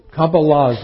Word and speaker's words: Kabbalah: It's a little, Kabbalah is a Hebrew word. So in Hebrew it's pronounced Kabbalah.